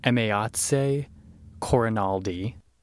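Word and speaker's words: Emayatzy Corinealdi: Just hopping on here real quick to say that I looked up the pronunciation because I was like, I couldn't leave it that way. I could not pronounce this poor woman's name right. It's Emyatsi Cornialdi.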